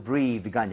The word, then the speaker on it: breath: 발음도 breathe가 아니라 breath.